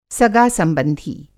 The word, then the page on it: सगासंबंधी: सगा-संबंधी; Hindi